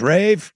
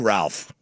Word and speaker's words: Ralph: And he goes, Rave, if you don't win tonight, we're going to start calling you Ralph. – Love it. – You don't deserve the correct pronunciation.